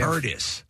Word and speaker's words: Turdus: Turdus. – 263 WMMR. And it's spelled like it sounds. T-U-R-D-U-S.